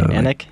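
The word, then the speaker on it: Annik: So Anik? How do you spell that? – It's A-N-N-I-K.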